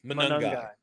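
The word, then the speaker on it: Monangai: with Kyle Monagai. I believe I said that right. Right? Am I correct on that? – Monagai. Yeah.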